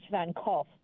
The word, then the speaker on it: Van Gogh: So over in the UK, a majority of people say Van Gogh. – Oh. – And that's because of the way that they have been pronouncing it since it was basically, you know, out and around. Mm. Everywhere else, a majority of people are going to say Van Gogh. Mm. However, it's actually pronounced Van Gogh.